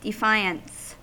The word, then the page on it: defiance: American English